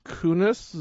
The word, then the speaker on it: Kunis: Anyway, the movie stars Mark Wahlberg and Mila Kunis. Is that how you say her name? – Yes.